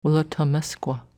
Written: Woolautaumesqua

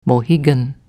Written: Mohegan

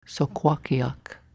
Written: Sokwakiak

